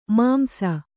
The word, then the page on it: ಮಾಂಸಿ: māmsi